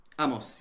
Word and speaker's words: Amos: Now, one of your frequent collaborators, and I want to pronounce his name correctly, is that Amos? – Amos, yeah.